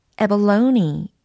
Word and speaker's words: abalone: abalone, abalone 이렇게 발음이 됩니다. abalone, 애벌레가 아니고요.